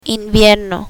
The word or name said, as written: invierno